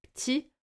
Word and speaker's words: petit: Słówko petit możemy wymawiać petit, usuwając to e.